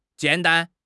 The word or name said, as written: jian3 dan1